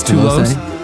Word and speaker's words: Toulouse: How would you say that? It's Toulouse. T-O-U-L-O-U-S-E. Toulouse. There you go.